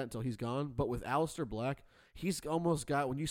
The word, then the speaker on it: Aleister Black: Aleister Black probably has the most potential, the most. Yeah, that's right. I said that right.